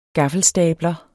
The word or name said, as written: gaffelstabler